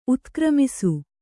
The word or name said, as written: ಉತ್ಕ್ರಮಿಸು